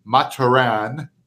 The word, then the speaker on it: Mathurin: Nobody really knows the right way to pronounce his last name. Benedict Mathurin, which he's from Quebec. He's French. It's actually pronounced officially Mathurin, okay? But nobody says that.